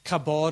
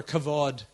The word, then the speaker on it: kavod: In the Old Testament, the Hebrew word is kabod, or kavod is actually the way it's pronounced.